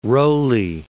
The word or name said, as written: Rowley